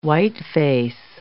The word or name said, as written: whiteface